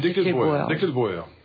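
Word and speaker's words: Desclée de Brouwer: comment prononce-t-on de... – Dekelbauer. Voilà.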